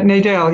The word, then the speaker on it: Nadel: It's pronounced Laurie Nadel? – Yeah, Nadel, yes.